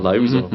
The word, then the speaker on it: Liuzzo: Viola Liuzzo. I'm not pronouncing that right. – Liuzzo mm-hmm.